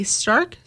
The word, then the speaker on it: Starck: S-T-A-R-C-K. Stark? – Yeah.